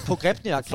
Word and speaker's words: Pogrebniak: Det var den russiske, og det er Pogrebniak. Pogrebniak. – Flot, du kan udtale det helt ud.